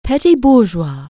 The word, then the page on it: petit bourgeois: anglais